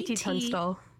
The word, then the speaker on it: KT Tunstall: KT Tunstall.